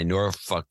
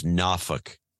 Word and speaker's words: Norfolk: And then my Norfolk Southern is pronounced Norfolk. Not Norfolk. – Norfolk. – I get a bunch of notes from people in the area. No, it's not pronounced that way. It's not Nor. It's Na.